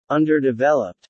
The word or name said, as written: Underdeveloped